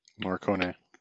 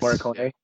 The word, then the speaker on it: Morricone: Morricone. – Yes. – Morricone.